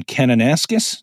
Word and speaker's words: Kananaskis: As Alvaro knows, I am a principal observer with the eagle migration count in Kananaskis.